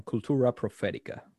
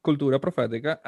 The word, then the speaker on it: Cultura Profética: And if I pronounce it a little bit more American, Cultura Profética. And the song... – You can keep it the way it is. – Yeah, Cultura Profética.